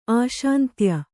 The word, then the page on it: ಆಶಾಂತ್ಯ: āśāntya